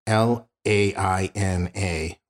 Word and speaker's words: Laina: Her daughter, and forgive me if I'm not pronouncing this correctly, her name is Laina, L A I N A, Laina, I believe I'm pronouncing that correctly, McMahon.